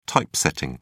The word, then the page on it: typesetting: British English